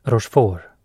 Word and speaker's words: Rochefort: Rochefort. Även kallad R-ansiktet. Namnet på den franske greven uttalas Rochefort.